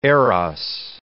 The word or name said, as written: Eros